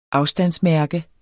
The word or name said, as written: afstandsmærke